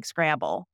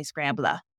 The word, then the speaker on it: Scrabble: And then consonant L-E, so like Scrabble, you swallow that, which is different. You don't say Scrabla.